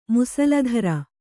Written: ಮುಸಲಧಾರೆ

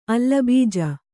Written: ಅಲ್ಲಬೀಜ

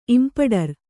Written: ಇಂಪಡರು

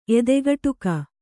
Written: ಎದೆಗುಟುಕು

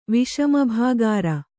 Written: ವಿಷಮಭಾಗಾರ

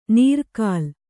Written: ನೀರ್ಕಲ್

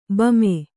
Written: ಬೊಮ್ಮಿ

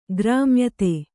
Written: ಗ್ರಾಮ್ಯತೆ

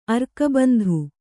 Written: ಅರ್ಕಬಂಧು

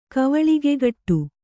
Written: ಕವಳಿಗೆಗಟ್ಟು

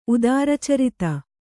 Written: ಉದಾರಚರಿತೆ

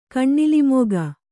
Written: ಕಣ್ಣಿಲಿಮೊಗ

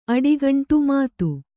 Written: ಮದು

